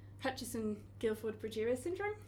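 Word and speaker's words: Hutchinson-Gilford progeria syndrome: And in particular, you've been working on this very devastating disease, which is called Hutchinson-Gilford-Progeria syndrome. – Yeah. – If I'm pronouncing that correctly, yes, perfect.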